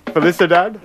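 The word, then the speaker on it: Felicidade: How do you say that? Felicidad? Yeah. Okay, thank you.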